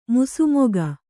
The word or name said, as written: ಮುಸುಮೊಗ